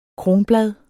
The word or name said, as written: kronblad